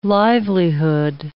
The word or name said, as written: livelihood